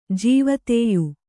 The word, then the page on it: ಜೀವದಯೆ: Kannada